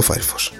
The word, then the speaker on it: Firefox: Firefox o Firefox, pronunciado Firefox.